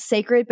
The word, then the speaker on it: Cecred: And by the way, it's called Sacred, but it's spelled C-E-C-R-E-D, like Beyonce.